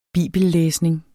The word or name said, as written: bibellæsning